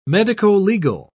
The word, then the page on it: medicolegal: British English